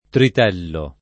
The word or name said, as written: tritello